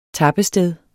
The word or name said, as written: tappested